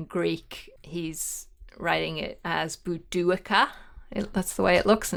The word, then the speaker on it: Boudicca: Jennifer Wien asks why the change in pronunciation, and I gather this is with Boudicca's name. – Mm. When I was a child, long, long ago, she was always Boadicea. We think Boudicca is probably the way she would have pronounced it.